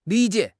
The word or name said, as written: li3 jie3